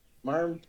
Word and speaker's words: Marmite: Is there something called Marmonette? Marmite. – Yeah, it's spelled Marmite.